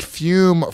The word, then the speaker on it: Fume: All right, gentlemen, let's take a quick moment and thank Fume for supporting today's show. It is spelled F-U-M, but is pronounced Fume.